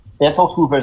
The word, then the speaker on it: Bertolt Hoover: Bertolt Hoover is correct.